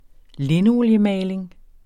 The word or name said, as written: linoliemaling